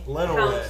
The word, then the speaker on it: Lenawee: Lenawee.